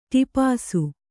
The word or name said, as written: ಟಿಪಾಸು